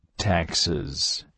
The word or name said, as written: taxes